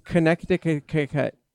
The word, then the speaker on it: Connecticut: From Connecticut.